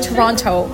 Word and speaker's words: Toronto: He pronounced the T. – Toronto? Toronto? – Yeah, he was like Toronto. – But he's from there. – Yeah, so he's supposed to be Toronto. No T.